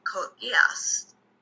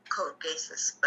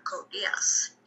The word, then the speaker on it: Code Geass: One of my friends actually recommended me an anime called Code Geass. Before, I thought it was called Code Geass, but that wasn't the right pronunciation.